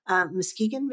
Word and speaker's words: Muskegon: I know this is going to Omaha, Nebraska, and Richmond, Virginia, Jackson, Michigan, Cincinnati, Ohio, and Muskegon, Michigan.